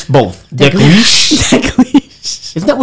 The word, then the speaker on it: Dalgliesh: Dalgliesh.